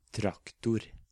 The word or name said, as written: traktor